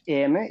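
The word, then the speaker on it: Oehme: Э, вкратце, значит, депутат Бундестага, член ФАСЕ Ульрих, как они пишут, Эме, я произношу его фамилию как Эме, э, что он был э, в Крыму на президентских выборах России в марте 2018 года года за счет Госдумы.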